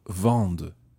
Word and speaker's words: vendent: And il or elle plural vendent. – Ils, elles vendent. – And vendre is spelled V-E-N-D-E-N-T.